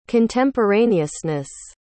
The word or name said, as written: contemporaneousness